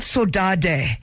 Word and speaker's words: saudade: Except it's spelled saudade. Yes. Saudade, yeah. But saudage, you know, that's how.